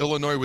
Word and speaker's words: Illinois: They go into Champaign, the city, not the drink, and they lose to Illinois with the S is silent, our opponent on a Friday night.